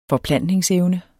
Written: forplantningsevne

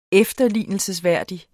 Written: efterlignelsesværdig

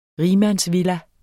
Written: rigmandsvilla

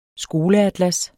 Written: skoleatlas

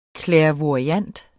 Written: clairvoyant

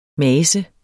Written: mase

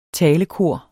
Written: talekor

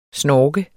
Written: SNORKEDES

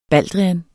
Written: baldrian

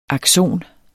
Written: akson

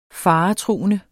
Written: faretruende

